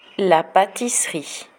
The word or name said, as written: la patisserie